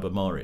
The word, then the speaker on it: Ahmari: David French and Sohrab Ahmari.